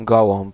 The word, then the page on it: pigeon: [pi.ʒɔ̃]; France